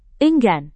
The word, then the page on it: enggan: indonesian